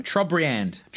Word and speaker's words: Trobriand: Trobriand. – That's definitely how you say it. – Well, T-R-O-B-I... Oh, I can't even spell it. So let's just move Trobian? – On. T-R-O-B-R-I-A-N-D.